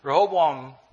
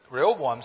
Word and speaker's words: Rehoboam: After Solomon's death, his son, Rehoboam, am I pronouncing that well? Okay.